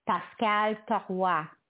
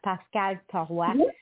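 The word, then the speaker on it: Pascale: Yeah, if you know, it's a French name, so you know it's probably pronounced Pascal Tarua, her name. If that's correct, yeah, Pascal Tarua. Yes. It's the same name. It's pronounced the same in the feminine and the masculine, the Pascal.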